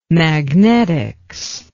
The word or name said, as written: magnetics